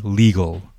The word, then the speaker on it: legal: And remember, it's not gal. Okay, it's a, again, this is a schwa sound. This is a gull. Okay, legal. And of course, the emphasis is on the first syllable, Lee. Okay, so that's why the second part is more not pronounced as strongly.